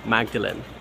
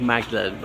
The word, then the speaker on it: Magdalene: And just behind me is the graveyard of St. Mary Magdalene. I believe the church is actually pronounced Magdalene, not Magdalen, unlike the college.